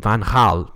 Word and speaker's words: Van Gaal: And I think Van Gaal, did I say that right? – Oh, yeah, that was good.